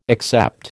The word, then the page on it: except: American English